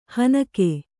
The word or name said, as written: ಹೆಣ್ಣುಗ